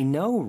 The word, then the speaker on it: know: I know, right? – 빈칸에는? – No, K-N-O-W. – 그쵸. K-N-O-W. No로 발음되지만 K는 묵음이죠.